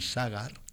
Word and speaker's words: Sagar: Pero abrimos la cajita con los joyas, esos nombres menos comunes, para felicitar a Sagar, con acento en la A. Hoy es San Sagar. – Sagar. – Sagar. Con S-A-G-A-R, así de sencillo, Sagar.